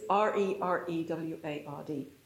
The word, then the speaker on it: rereward: And it's not spelt with a hyphen, it is R-E-R-E-W-A-R-D, re-reward.